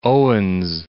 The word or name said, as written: Owens